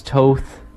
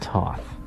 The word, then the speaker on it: Toth: Remember, people, that's Toth, not Toth. – T-O-T-H. – Not like the planet Hoth. – Okay. – So, let's go through a little bit of timeline of Mr. Toth.